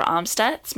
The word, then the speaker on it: Amstutz: You can find me on all social platforms at Sandra Amstutz. My last name is spelled AMSTUTZ, and I will be posting our 4th of July celebrations on Snapchat.